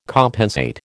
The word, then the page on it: compensate: American English